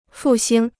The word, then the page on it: 复兴: fù xīng